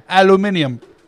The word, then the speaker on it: aluminium: Can you say it like you're British? – I can't. – Aluminium.